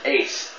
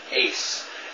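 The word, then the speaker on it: ace (εἰς): Now that word is ace. E-I-S is the way you write it. Ace. It's pronounced like we would probably pronounce A-C-E, ace. But it's I-E-I-S in the Greek.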